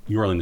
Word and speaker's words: Jorlan: Yorlin is spelled J-O-R-L-A-N. – Correct. – Soft J's are a thing, thank you.